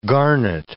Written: garnet

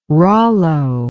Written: rollo

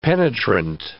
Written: penetrant

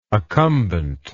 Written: accumbency

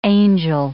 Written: angel

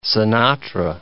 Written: Sinatra

